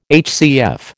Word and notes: HCF: American English

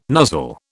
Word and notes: nuzzle: British English

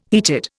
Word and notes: heated: Indian

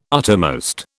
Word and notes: uttermost: British English

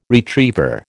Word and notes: retriever: American English

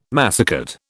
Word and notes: massicot: British English

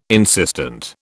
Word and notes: insistent: British English

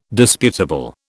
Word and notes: disputable: British English

